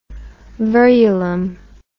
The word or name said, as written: verulam